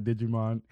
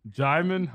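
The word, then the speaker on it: Djimon: I'm already laughing because I'm still going to say Digimon, even though the correct way to say his name, is? – Jaimon Hansu.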